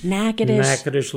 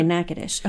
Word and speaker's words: Natchitoches: During my junior year, I actually was a sort of a pastor of a little church outside of Natchitoches, Louisiana. – Natchitoches, Louisiana. – Did I say it right? – Yes, you did. – Okay, Natchitoches.